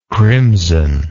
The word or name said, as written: crimsonness